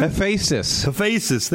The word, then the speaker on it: Hephaestus: Hephaestus, there you go.